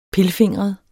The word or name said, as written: pilfingret